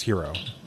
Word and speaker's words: gyro: Okay, well, a gyro is not pronounced gyro anyway. It's hero.